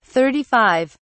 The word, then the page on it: 35: British English